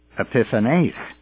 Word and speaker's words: Epiphanes: Now, by the way, most people pronounce the Syrian ruler Antiochus, but the Greek pronunciation is Antiochus Epiphanes, or Epiphanes, with the accent at the end of that word.